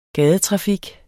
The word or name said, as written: gadetrafik